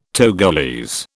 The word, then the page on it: Togolese: British English